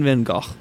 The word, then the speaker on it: van Gogh: Those were the lyrical musings of Don McLean with Vincent, a song about Vincent van Gogh, or if you're fancy, Vincent van Gogh. But I'm not fancy, so I don't say it like that.